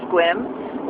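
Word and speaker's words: Sequim: Just went through Squim, which is, you know, we have lots of native names around here, and Squim you would not think is pronounced Squim.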